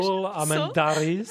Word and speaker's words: Saúl Armendáriz: Saul. Mm-hmm. Is that correct pronunciation? – Saúl Armendáriz. Yeah.